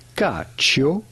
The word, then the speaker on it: cacio: How do you go about pronouncing it? Cacio. The C here is pronounced like C-H. Cacio.